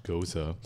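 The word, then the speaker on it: Gose: And they point out that it is pronounced Goza.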